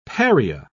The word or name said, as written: pariah